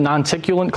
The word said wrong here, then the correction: noctilucent: Noctilucent? – Nocticulent cloud? Noctilucent. Yeah, yeah. I've mispronounced it like 1,000 times.